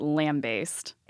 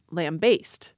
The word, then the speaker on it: lambaste: Now, when I went and looked this up in standard dictionaries, what I was struck by is that the stress in standard dictionaries is always put onto the second syllable, as in lambaste.